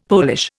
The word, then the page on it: Polish: Indian